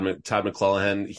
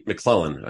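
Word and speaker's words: McLellan: The Kings, well-coached, Todd McClellan. McClellan, I said the name wrong.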